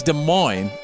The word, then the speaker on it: Des Moines: And it is Des Moines, Iowa.